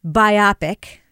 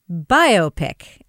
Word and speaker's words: biopic: The way that the kind of film that portrays somebody's biography is pronounced is not biopic, as Kevin always says. It is biopic, as in a bio picture.